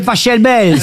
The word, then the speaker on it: Pachelbel: Ça s'écrit P-A-C-H-E-L-B-E-L. – Mais il adorait l'Italie. – Mais par contre, Laurent, s'il est allemand. – Cannelloni, c'est Pachelbel. – S'il est allemand, ça se dit Pachelbel.